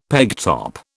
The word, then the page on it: pegtop: British English